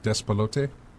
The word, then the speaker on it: Despelote: Best game beyond entertainment was, I'm going to mispronounce this, probably Despelote. – Good job. – Yeah, did I get it right? – You got it right.